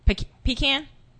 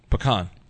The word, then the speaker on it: pecan: How do you say pecan? I'm like, pecan or pecan? – Pecan.